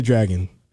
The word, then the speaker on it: Dragun: Have you ever heard of Nikita Dragon? – I can't say I have. – Can you pull her up on the screen? Just Google Nikita Dragon. – Sure. – And Dragon is spelled Dragun.